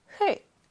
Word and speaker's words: 흙: 대부분 그렇다는 것이지, 예를 들어서, 흙, 흙이라는 낱말이 있다면, 뒤에 있는 받침인 기역으로 소리가 난다. 흙, 이렇게 소리가 났죠?